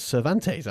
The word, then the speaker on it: Cervantes: Cervantes. I liked your pronunciation there.